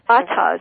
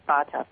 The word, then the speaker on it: Fatah: Fatah is how you say it. And he... – The organization, the political party I think you're thinking of, Carol, is Fatah, F-A-T-A-H, I'm sorry.